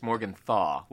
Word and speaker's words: Morgenthau: Well, that's a good question, because I think on IMDb it's got an L in it, and so I said it wrong in like 25 interviews at the TCAs, but apparently it's Morgenthau, like just without the L.